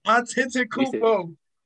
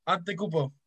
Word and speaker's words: Antetokounmpo: Well, how you say his name? – Hey, it's Antetokounmpo. It's two T's in it. I'm saying it close. Antetokounmpo.